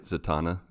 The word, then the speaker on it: Zatanna: Zatanna, right? I'm like saying it right. Zatanna. Not Zatanna or Santana. Zatanna. Zatanna. Yes. Zatanna.